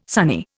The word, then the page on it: sunny: Indian